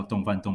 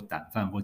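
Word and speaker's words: 丼飯: 原来这个字念作" 胆"，或者是破音字念" 井"，所以理论上应该叫做烧肉胆饭，或者是烧肉井饭。好，但是 anyway，可能是日文的关系，所以我们就是将错就错，日文那个字啊会念作洞，所以我们台湾人也就跟着叫洞饭洞饭洞饭，对。但是其实那个字念作胆饭或井饭，哦。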